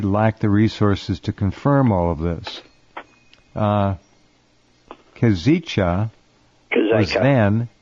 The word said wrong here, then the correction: Kazika: I think it's pronounced Kazika, but what do I know? – Kazika.